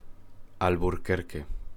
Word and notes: Alburquerque: Spanish

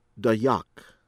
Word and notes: Dayak: American English

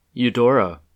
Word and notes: Eudora: American English; Midwestern accent

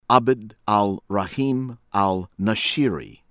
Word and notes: Abd Al-Rahim Al-Nashiri: American English

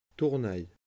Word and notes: Tournay: French

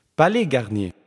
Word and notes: Palais Garnier: palɛ ɡaʁnje; franska

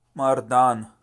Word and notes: Mardan: Pashto

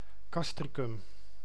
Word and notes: Castricum: ˈkɑstrikʏm

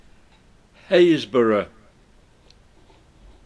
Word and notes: Happisburgh: ˈheɪz.bɜːrə; HAYZ-bur-ə

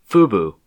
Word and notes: FUBU: U.S. English; Midwestern accent

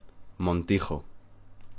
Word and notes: Montijo: Spanish

